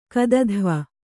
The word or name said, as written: ಕದಧ್ವ